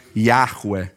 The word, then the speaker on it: Yahweh: que a gente pronunciaria alguma coisa como Yahweh, então, quando Deus ele se apresenta, ele diz: Eu sou.